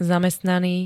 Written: zamestnaný

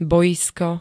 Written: bojisko

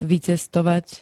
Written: vycestovat

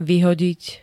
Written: vyhodiť

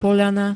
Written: POĽANA

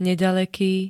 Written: nedaleky

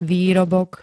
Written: výrobok

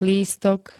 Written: lístok

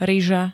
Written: ryža

